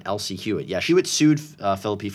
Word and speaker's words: Phillippe: It's about Ryan Phillippe, and I'm going to go with Phillippe. That's how we say his name in my book.